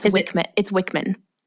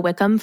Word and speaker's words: Wickman: Also, I just have to say this because I have mispronounced his name for like 2 years. It's Wickman. – Then why on our document does it say Wickham? – Because I think we both have called him Gino Wickham forever.